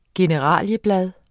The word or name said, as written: generalieblad